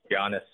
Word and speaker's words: Giannis: So yeah, Giannis, yep. – Was he 15 or 14 or somewhere around there? – It was somewhere around there. It's funny. I'm still a little traumatized about the whole Giannis thing because, you know, coming into the draft, actually the Hawks were very interested in drafting him.